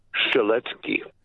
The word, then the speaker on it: Strzelecki: Strzelecki is actually pronounced Strzelecki. – Strzelecki. – Strzelecki, because it is a Polish name.